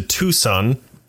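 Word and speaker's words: Toussaint: You hear Nakia talking to Tucson. That's how it's pronounced.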